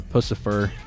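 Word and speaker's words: Puscifer: Now, if you don't know, he actually, of course, not only sang in such bands as Tool and Perfect Circle and also Pussifer. Yes, I said that correctly.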